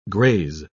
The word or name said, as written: graze